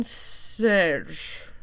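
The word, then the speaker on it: Šejić: If you've ever seen the name STJEPAN, last name SEJIC, apparently you say that Steven Serge. I don't know how to say the G noise, but I was told by a creator who was told by a Dutch person that is how you say it. The G is kind of a zzz, you know, kind of sound. So Steven Serge. Apparently that's how that's said, but he has a variant cover as well.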